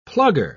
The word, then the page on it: plugger: British English